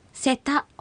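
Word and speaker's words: セタ: せたほゆぷ、せたほゆぷ、せた、これは犬ですね。 – はい。 – アクセントはたに意識して発音してください。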